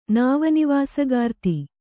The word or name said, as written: ನಾವನಿವಾಸಗಾರ್ತಿ